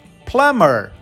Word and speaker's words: plumber: P-L-U-M-B-E-R. Mas a gente não fala plumber, a gente fala plumber. Cadê o B? O B sumiu. Escafedeus.